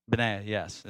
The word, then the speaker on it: Benaiah: How did I say it last time? Benaiah, didn't I? How do you say it? Benaiah, yes. It's how it looks like it's pronounced, if I can even understand that in the definitions of the highs, lows.